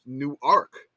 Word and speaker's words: Newark: He said, "We pronounced Newark wrong. ! The city in Delaware is pronounced Newark. So it's Newark, Delaware, which is different. – Newark, Delaware, mm-hmm. which is different from the city in New Jersey.